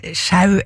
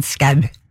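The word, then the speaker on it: Schous: Og uansett, ja, altså, jeg tror virkelig ikke det noen grunn til å uttale det øllet som hverken sjau eller show eller noe som helst annet enn skau.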